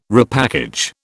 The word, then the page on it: repackage: British English